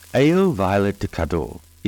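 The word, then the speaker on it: Ail violet de Cadours: Ale Violet de Cadore is spelled ale, A-I-L, violet, V-I-O-L-E-T, de, D-E, Cadore, C-A-D-O-U-R.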